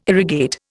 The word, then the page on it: irrigate: Indian